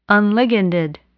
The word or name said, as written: unliganded